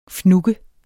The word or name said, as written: FNUGGE